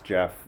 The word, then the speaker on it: Geoff: It's, before we get too far, it's actually pronounced Jeff.